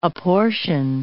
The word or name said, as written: apportion